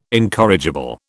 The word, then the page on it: incorrigible: British English